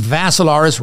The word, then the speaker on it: Vassilaris: Go to my profile page on Facebook, Dimitri with three I's, D-I-M-I-T-R-I. Last name, Vassilaris, rhymes with Asilaris, except you had a V.